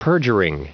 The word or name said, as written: perjuring